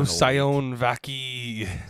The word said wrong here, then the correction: Sione Vaki: Aaron, as the owner of this man, how do you pronounce his name? – It's Sione Vaki.